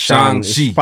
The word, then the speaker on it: Shang-Chi: It's Shang-Chi.